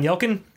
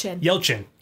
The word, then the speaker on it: Yelchin: Brad Dourif is not Anton Yelchin. – Yelchin.